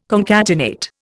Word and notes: concatenate: Indian

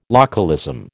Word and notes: localism: American English

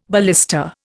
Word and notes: ballista: Indian

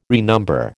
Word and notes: renumber: American English

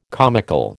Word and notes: comical: American English

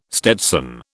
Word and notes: stetson: British English

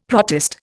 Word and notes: protist: Indian

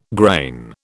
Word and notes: grain: British English

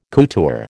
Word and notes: couture: American English

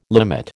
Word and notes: limit: American English

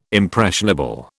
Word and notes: impressionable: British English

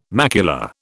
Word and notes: macula: British English

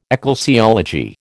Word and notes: ecclesiology: American English

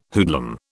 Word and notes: hoodlum: British English

